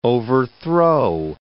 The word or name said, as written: overthrow